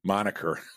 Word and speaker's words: moniker: And the reason they came up with an alternative monkier moniker, I'm sorry, you can tell I learned that word through reading because I mispronounced it, which is nothing to be ashamed of because all it does is show that I read.